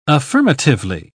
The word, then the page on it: affirmatively: British English